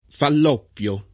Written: Falloppio

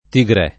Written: tigrè

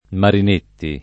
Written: Marinetti

